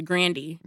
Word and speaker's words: Grande: And her name is pronounced Grandy.